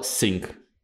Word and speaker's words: sync: А, тонуть это будет S-I-N-K, так же, как кстати, как и раковина, а синхронизироваться будет S-Y-N-C. Но звучат они, да, sync.